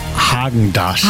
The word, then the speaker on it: Häagen-Dazs: Spricht man das mit R oder mit A aus? – Man spricht es mit A aus. Ich sage auch, es heißt Häagen-Dazs.